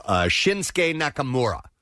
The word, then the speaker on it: Shinsuke Nakamura: So we will have, and I've been told the proper pronunciation because I messed this guy's name up, Shinsuke Nakamura is going to be here this morning, and Sami Zayn is going to be here.